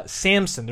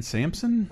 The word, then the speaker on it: Samson: Samson. There's no P. That's what's very hard when I say this is little Samson. – Samson. I was way off. – You pronounce the P in Samson?